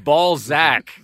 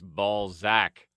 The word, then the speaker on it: Balzac: B-A-L-Z-A-C. – Z-A-C. That's Ballsack, not Ballsack, Alberta.